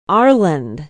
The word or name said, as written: arland